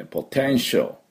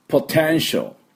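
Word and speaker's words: potential: ポテンシャルでね、ポテンシャル。しってそのまま L とダイレクトにつないでもいいぐらいです。ここ、この 3 つ。 P の音。 T、手のところにアクセント、それからシャオをうまく言えれば綺麗な発音になります。で、私の後について発音しましょう。ポテンシャル。ポテンシャル。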